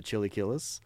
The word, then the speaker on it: chilaquiles: You know, my wife loves the chilaquiles.